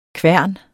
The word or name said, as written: kværnen